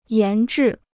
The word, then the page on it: 研制: yán zhì